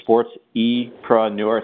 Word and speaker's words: SportsEpreneur: Perhaps even, if you checked out our Instagram account, Jared was on our Instagram account on the Sports Epreneur. That's how you pronounce it, Anchor Sports Epreneur. It's not that hard, really, when you think of it.